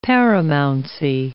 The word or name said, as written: paramountcy